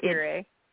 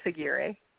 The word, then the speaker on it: Aguirre: Because there's so if you guys go to her Instagram, it's Ann underscore Aguirre underscore author. Uh, uh, I'm sorry, Aguirre. Aguirre. Um, no, it's Aguirre. – Aguirre.